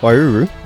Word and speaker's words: Waiouru: But Wairua was uh, really interesting, actually. – Waiouru? – Sorry, yes, not very good at pronunciations yet.